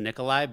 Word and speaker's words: Nikolaj: I think it's pronounced Nikolai, but it ends with a J. So I always... – What? – I just call him Nikolaj. – Swedish? – Nikolaj.